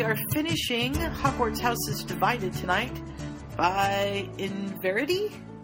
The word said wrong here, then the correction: Inverarity: Inverarity. – Inverarity. – So you just missed one syllable.